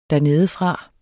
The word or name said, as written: dernedefra